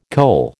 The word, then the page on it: coal: American English